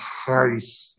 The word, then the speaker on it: charis: It's actually pronounced more something like charis, you know, with that strong H sound.